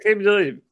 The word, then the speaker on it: Aqib Talib: Steve Smith was just a big play, and him and Aqib Talib or Talib, yeah, because not Aqib. – Yeah, he's saying it right. Aqib Talib.